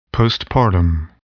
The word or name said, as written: postpartum